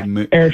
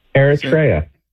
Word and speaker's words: Eritrea: Well, there's an African country just north of Ethiopia, and it's spelled E-R-I-T-R-E-A. – Yeah, Eritrea. Yeah. – How would you say that? – Eritrea. – Eritrea. Thank you.